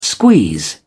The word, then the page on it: squeeze: British English